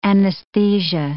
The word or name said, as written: anesthesia